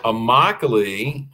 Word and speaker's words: Immokalee: So it's Immokalee. That is a word from the Seminole Indian language, and it means my home. You can always tell the name, pronounce the name of an Indian word because every letter is pronounced. Nice. Every letter is articulated. So there's nothing that remains silent.